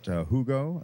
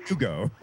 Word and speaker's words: Hugo: Scott, let's talk about Hugo. It's, what am I talking about? I just pronounced it as if I was Pedro Almodovar. Hugo.